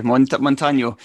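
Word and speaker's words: Montaño: Yeah, because he's got a wee, I don't know what they call it, but a wee accent above the N. – Okay, Montano.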